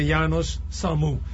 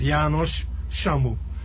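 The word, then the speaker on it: János Samu: They pronounce it here Janos Samu. But the original pronunciation in my native language is Janos Samu.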